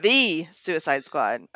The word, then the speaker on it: The Suicide Squad: The Suicide Squad. – Emphasis on the article. – On the correct syllable.